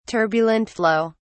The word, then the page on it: turbulent flow: British English